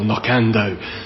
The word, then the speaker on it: Knockando: We don't know why it's called that, but it's also stressed on that second syllable, Knockando, as if it was the Black Hill, it'd be Knockando, is how you pronounce it in English.